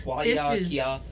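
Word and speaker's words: Waiakea: Wahakia. I think I said that good. I nailed it. Okay. – Yeah. Wahakia. How do you say that, Miss Expert? – Yeah, she got it right.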